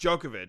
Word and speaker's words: Djokovic: I pronounced it Djokovic. Djokovic. – Yeah, something like that. No, it's just Djokovic.